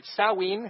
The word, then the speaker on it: Samhain: We are here celebrating, Samhain. I think that's the way the Wiccans pronounce it, with a wonderful Wiccan community.